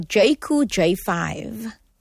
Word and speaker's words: Jayco J5: Talking about, I mean, I hope I pronounced it correctly also, Jayco J5.